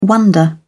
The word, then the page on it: wonder: British English